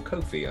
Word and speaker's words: Ko-fi: you can go to our Ko-fi page, or Ko-fi, I think it is, because it rhymes with no fee.